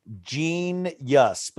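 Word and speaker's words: Jeen-yuhs: called Gene Yus, but it's spelled Jeans, like Jesus. Gene Yus.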